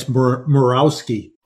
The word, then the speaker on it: Morawski: Yeah, it's Morawski.